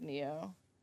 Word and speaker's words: Nioh: I haven't even heard of Neo. – It's spelled N-I-O-H.